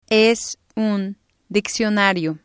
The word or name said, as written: es un diccionario